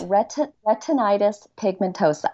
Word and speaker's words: retinitis pigmentosa: You are so close. I know it's tricky. It's retinitis pigmentosa.